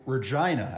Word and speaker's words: Regina: Regina, actually. Regina. – Is that true? That's how it's pronounced? – Yes, it is, actually.